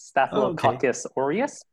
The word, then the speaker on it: Staphylococcus aureus: it's called Staphylococcus aureus. Is that the correct pronunciation? – You're really close. It's Staphylococcus aureus, but...